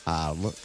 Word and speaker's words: Arles: E la S? – Non si pronuncia. – Arles in Francia.